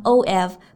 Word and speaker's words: of: 然后注意呢，of 它末尾的发音其实并不是 f，而是一个 v，所以呢，可以和后面的 herself 进行一个连读。